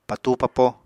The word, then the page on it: patúu-papo: apatani